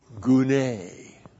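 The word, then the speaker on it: gune (γυνή): It sounds a lot like gune. It's gune. To help you out, I call it June. G-U-N long E.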